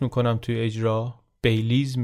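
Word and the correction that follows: Belize: میگم باید مثلا بیشتر نزدیک به بلیز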